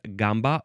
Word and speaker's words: gamba: G-A-M-B-A. It can be pronounced gamba or gamba.